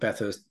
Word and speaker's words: Bathurst: fun fact about Bathurst Harbour, and I know, Bathurst Harbour, did I say that right? Yeah. – Yeah, like Bathurst, the racetrack.